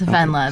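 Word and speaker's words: Wendela: Yes, Vendela. Okay. The German W is a V.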